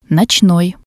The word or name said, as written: notsnoi